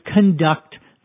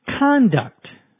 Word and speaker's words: conduct: There's also a noun which is pronounced somewhat differently, conduct. Notice the difference between the verb, conduct, where the accent is on the second syllable or part of the word, and the noun, conduct.